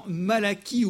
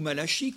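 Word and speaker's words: Malachie: Cette autre promesse, nous la trouvons dans Malachi ou Malachi, comme vous voudrez, vous ferez la prononciation à votre goût.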